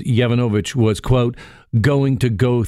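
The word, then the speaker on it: Yovanovitch: ambassador to Ukraine, Marie Yavankovych, I think I mispronounced that, Yavanovich, pardon me, says she was devastated when she learned that President Donald Trump wanted to remove her from her post.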